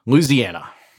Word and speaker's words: Louisiana: how do you say it? – Uh, I say it like a native born son of the South, Louisiana.